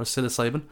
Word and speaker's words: psilocybin: Am I saying that right? Psilocybin or psilocybin? – Yeah, no, you're right, yeah, psilocybin.